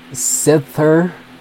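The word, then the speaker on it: Sithter: Next, I'm going to play Sither. Yeah, it's S-I-T-H-T-E-R. That's kind of an odd one to pronounce. Sit there, and then after that is Doobies.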